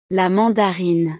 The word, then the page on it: la mandarine: French